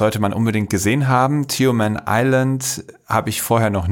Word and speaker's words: Tioman: Tioman Island.